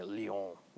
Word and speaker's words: Lyon: So any questions about the Fourth Lateran Council before we switch to Second Lyon? It's more fun to say that way, Lyon. Like all French things, you say it through your nose and it's more fun.